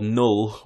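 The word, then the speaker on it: Knull: He's called Null with a K, a silent K.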